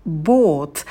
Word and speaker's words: bought: Buy, bought, bought. Когда вы произносите первый глагол buy, то на дифтонге I опускаете челюсть вниз и оттягиваете язык назад. А в bought будет долгий о, как такая звуковая волна.